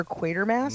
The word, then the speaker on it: Quatermass: I don't even know if that's how you say it, but there's a nod to Dr. Quatermass.